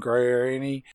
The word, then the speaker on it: Guarini: Note to self, go find a whole lot of stuff out about Kelly Clarkson and Justin Guarini and American Idol and singing competitions.